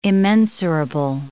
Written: immensurable

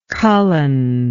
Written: cullen

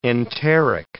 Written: enteric

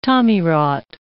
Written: tommyrot

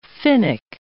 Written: Finnic

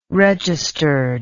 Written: registered

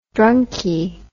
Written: drunky

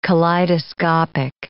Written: kaleidoscopic

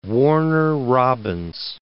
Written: Warner Robins

